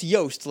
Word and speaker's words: Yost: Yep, and it's pronounced Yost like toast.